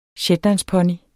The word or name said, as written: ponyen